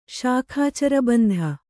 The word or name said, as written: ಶಾಖಾಚರಬಂಧ